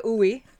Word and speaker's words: oui: I pronounced it oui. Somebody on my Facebook when I got there said, "Oh, ooey ooey," and I was like, "What is ooey ooey?"